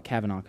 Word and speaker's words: Kavanah: The actual proper way to pronounce it, because we're, you know, I like to, I, I, had Hispanic family growing up, so I, I call it Americans gringos. But since we're gringos, we kind of mispronounce foreign languages. It's actually, I, I believe it's pronounced Kavanah, but um, we'll call it Kavanah because it's a little easier to say.